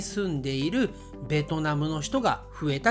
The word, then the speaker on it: ベトナム: ルさんのプロナンエーションがなんとなくねちょっとこうエグザジュレーションしている感じがします、えー、日本人の言い方はベトナムと言いますビエトナムではなくベトナム